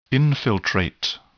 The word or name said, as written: infiltrate